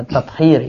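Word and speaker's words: tathir (تطهير): Dr. Soleh Al-Musallam saat menulis tesisnya yang berjudul Qatir An-Najasat sempat mengadakan kunjungan ke peternakan ayam Al-Wataniyah untuk memastikan. – Ya, itu bacanya tathir ya, tathir An-Najasat.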